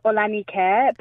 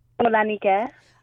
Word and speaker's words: Ola Nike: My name is Ola Nike, it's Nike, but it's spelled like Nike. – Yeah. – So like the... – It's the gods. – The African way is Nike. The gods.